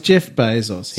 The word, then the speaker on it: Bezos: Jeff Bezos.